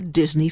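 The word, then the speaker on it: D'Isigny: The book's title, as we've said, is The Other Disney, D-I-S-N-E-Y. And all along, we meet the Disney family. D apostrophe I-S-I-G-N-Y, pronounced Disney 2.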